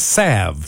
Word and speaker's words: salve: Nobody pronounces the L in S-A-L-V-E. – It's salve.